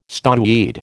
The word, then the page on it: stoneweed: American English